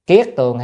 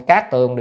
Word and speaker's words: kiết tường: Kiết tường hay cát tường đều được nha, nhưng mà trong nhà Phật thì chúng ta hay đọc kiết tường đó. Còn ngoài đời người ta đọc là cát tường, thì chúng ta đọc nên đọc là kiết tường.